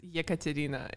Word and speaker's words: Ekaterina: Hi, did I say your name right? – Yeah, you pronounced it. – Yay, I had a little help in the beginning, but I just had to go with it. I felt like you just got to roll it off your tongue. – Yes, it's EKATERINA. It's difficult to pronounce, especially for American people.